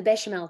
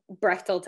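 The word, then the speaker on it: Bechdel: And it's not called the Bechamel test. It's called the... – That's how you pronounce it. Brechtel? – The Brechtel test. I say Bechamel because that's what it looks more like.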